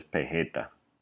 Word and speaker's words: peseta: Many Puerto Ricans will pronounce this word as pejeta.